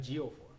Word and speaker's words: Ejiofor: Ejiofor.